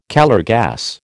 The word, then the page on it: Calor gas: American English